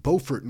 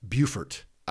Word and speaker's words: Beaufort: Now, there is a city with the exact same spelling in South Carolina, but there it's pronounced Beaufort. I'm here to let y'all know this important stuff. Beaufort, North Carolina, Beaufort, South Carolina, spelled the same.